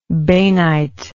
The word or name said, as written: bainite